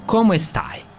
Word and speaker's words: come stai: Ciao Azra, come stai? – Bene, grazie. – Jesam dobro izgovorio ovo? – Dobro si izgovorio, jesam.